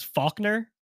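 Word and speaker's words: Falkner: I remember as a kid always being afraid to say his name, because it sounds like fucker, but it's not. His name is Faulkner,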